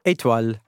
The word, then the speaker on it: Étoile: How do you pronounce it? – Étoile. – Étoile.